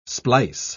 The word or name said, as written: splice